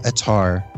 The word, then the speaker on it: Attar: And our thanks to the entire team at Pulse Media for their support, including Marie Rittenhouse, our resident marketing strategist, and Gloria Attar, rhymes with guitar, our social media marketing manager.